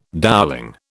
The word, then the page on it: dowelling: British English